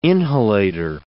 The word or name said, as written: inhalator